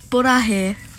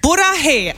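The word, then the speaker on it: Burahe (보라해): Hi, das wird Burahe ausgesprochen. – Burahe, also das sagt man in der BTS Army.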